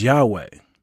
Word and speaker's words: Yahweh: We translate that word Lord from the Tetragrammaton or YHWH, and we pronounce it as Yahweh.